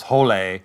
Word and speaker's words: Hole: And of course, in the book, it's actually pronounced, his last name is pronounced Hole.